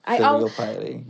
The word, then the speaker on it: filial piety: Filial piety. Did I say that right? – Filial piety, yeah.